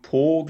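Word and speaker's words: Pau: Genau, als da in Po, glaube ich, wird es ausgesprochen.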